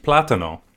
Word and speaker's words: plátano: Or as they say in Spanish, plátano. Why are you laughing? They do. – Really? – Yeah. – That's the pronunciation? – Plátano.